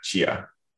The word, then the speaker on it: Tchia: And one more, and I'm going to probably mispronounce this. Chia? T-C-H-I-A. – I believe that's how they were pronouncing it. Yeah, Chia.